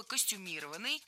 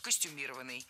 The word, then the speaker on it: костюмированный: Много раз прозвучало слово «костюмированный» с ударением на третьем слоге. И сейчас это верное ударение. Но раньше нужно было говорить «костюмированный». Однако на данный момент это слово с таким ударением уже помечают как устаревшее. И правильно делают. Современная норма только «костюмированный». Хорошо, что некоторые исправления вошли в новые словари.